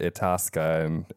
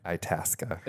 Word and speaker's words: Itasca: I kept calling it Itasca, and I guess that's not how you say it. – That's the name of the city in Illinois where you are? – Itasca.